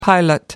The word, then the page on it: pilot light: British English